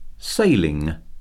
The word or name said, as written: sailing